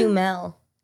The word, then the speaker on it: Duhamel: It's Duhamel. – It's Duhamel? – Yeah. – Yeah, not Duhamel anymore. It's just, wait, it's spelled D-U-H-A-M-E-L. – Yeah, Duhamel.